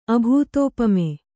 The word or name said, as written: ಅಭೂತೋಪಮೆ